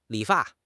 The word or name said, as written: li3 fa4